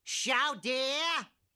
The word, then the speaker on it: Chowder: Chowder. Chowder. It's Chowder. Say it right.